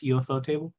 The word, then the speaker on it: Ufotable: Well, everyone's favorite ridiculous studio, Ufotable or Ufotable. I've never been 100% sure how to pronounce it. – I'm pretty sure it's Ufotable.